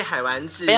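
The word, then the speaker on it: 海玩子: 哦，对，海丸子，没有没有发音不正确，它真的是玩乐的玩。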